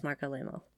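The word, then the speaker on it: Marc Alaimo: And I didn't say his name right, it's Mark Alamo.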